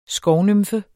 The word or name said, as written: skovnymfe